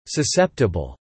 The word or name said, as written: susceptible